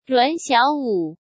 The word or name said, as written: Ruan Xiaowu